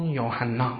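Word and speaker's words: یوحنا (יוחנן): کلمه یحیی، الان این تلفظ تلفظ عربی است، استحضار دارید، اما این نام عربی نبوده است، این کلمه عبرانی است نه عربی، و تلفظ دقیق آن یوحنا است.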